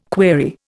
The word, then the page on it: query: Indian